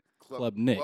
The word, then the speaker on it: Klubnik: Club Nick.